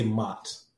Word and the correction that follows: Mayotte: but clearly there's another pronunciation that is better than my mispronunciation. Say that for us again. – Mayotte. – Mm-hmm. Mayotte Adorned. Okay. Yes. See, look at that. I've learned something new already. Go ahead.